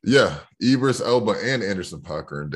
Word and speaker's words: Idris: Yeah, I think Ibris, how do you say his name? Idris Elba.